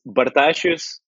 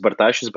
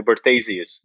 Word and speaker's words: Bartašius: Oh, it's it's Bartašius. If you follow Lithuanian pronunciation, it's Bartašius, but like you can call me Bartasius, just like as you read it.